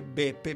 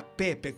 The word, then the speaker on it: Peppe: In Ancona non si dice Beppe, ma Peppe, con la P, Peppe.